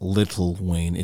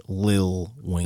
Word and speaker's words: Lil Wayne: It's not Little Wayne. It's Lil Wayne.